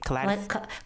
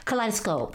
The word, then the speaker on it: kaleidoscope: that, especially with the kaleidoscape. Did I say that right? Kaleidoscape. – Kaleidoscope. – Kaleidoscope.